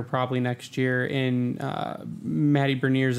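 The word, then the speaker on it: Beniers: You got to say it right. It's Matty Berniers.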